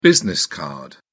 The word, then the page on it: business card: British English